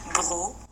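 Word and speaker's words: Breaux: Bro. Oh, bro. I feel like bro. I was saying it right.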